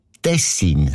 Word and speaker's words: Tessin: Also klar, Tessin. Aber der SRF-1-Hörerin Regula Stern-Grieser von Locarno ist aufgefallen, dass gewisse Leute statt Tessin Tessin sagen, also Betonung auf mehr, mit einem kurzen I.